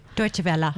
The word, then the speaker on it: Deutsche Welle: I'm so excited, and I want to congratulate on her recent acceptance into a Deutsche Wells program in, or Deutsche Welle, I think that's the right way to say it, isn't it? – Deutsche Welle. – Deutsche Welle, thank you.